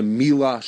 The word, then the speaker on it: Miele: to the Joy of Kosher tasting at the Mila showroom. Now I thought it was Miel, M-I-E-L-E, but it's pronounced Mila.